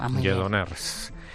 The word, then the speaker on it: Lledoners: Es la referente a la llegada mañana de los políticos presos a la cárcel de Lledoners. ¿Es correcto como lo he pronunciado? – Sí. – Lledoners. Sí.